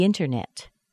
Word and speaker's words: internet: Internet. Do you hear the T in that? It's spelled with a T, but you can't hear it. You don't hear the T because it's dropped. We've got an N plus a T, plus an unstressed syllable, and that T isn't heard.